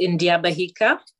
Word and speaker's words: Ndyabahika: Yeah, Ruth, how do I say your how do we say your last name so we get that right? – All right. So it's India Bahika, but I usually say just break it up into two bits.